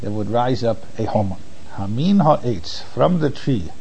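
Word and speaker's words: Homon (המן): So therefore that word can be pronounced Homon.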